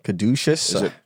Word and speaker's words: caduceus: Caduceus.